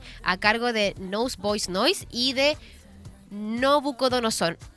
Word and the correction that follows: Nabucodonosor: Nobuc Ay, tú no sabes pronunciar bien el Nabucodonosor. Nabucodonosor.